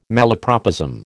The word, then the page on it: malapropism: American English